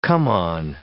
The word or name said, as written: come-on